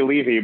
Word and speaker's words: Levy: Is it really like Jonathan Levi? – I usually say Levy, but as long as Levy.